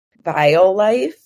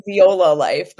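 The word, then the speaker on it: Violife: I never ever pronounce it right. I'm always like Viola Life, but that is not how you say it.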